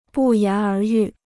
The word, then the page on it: 不言而喻: bù yán ér yù